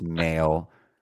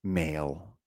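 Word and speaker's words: Mahle: And once again, just been butchering his name for years. Of course, it's Mail. Mail. Tyler Mail.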